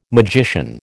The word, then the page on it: magician: American English